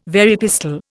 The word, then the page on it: Very pistol: Indian